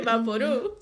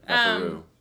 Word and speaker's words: VapoRub: It's a Vaporub? – Vaporub. That's how you say it, Vaporub. – Sorry, Vaporub.